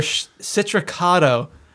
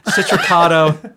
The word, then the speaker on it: Citracado: It's called their Citracado IPA. It rhymes with Chicago. Citracado.